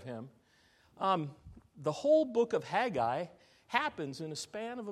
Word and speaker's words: Haggai: Not Haggai. It's Haggai, all right? And I can't say it like it's pronounced in Hebrew. Haggai.